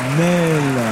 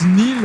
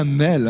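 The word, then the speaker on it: Néel: Bravo, Alexandra David Nel. Et d'ailleurs, son patronyme, parfois, on le prononce Nil, alors qu'il n'y a aucune raison, puisque c'est bien Nel, hein.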